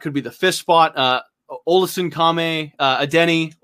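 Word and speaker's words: Olasunkanmi Adeniyi: Olesen Kame Adeniyi. – Okay. – I learned how to say his name, so I say every chance I get. – I thought it was just a Denny.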